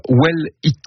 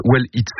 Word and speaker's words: WE2LEAT: Alors, près d'une semaine après la rentrée scolaire, dans l'écho d'ici, Nicole, vous nous faites découvrir Well Eat. Well Eat, c'est comme ça que ça se prononce, W-E-2-L-E-A-T, Well Eat, ce qui signifie bien manger en anglais.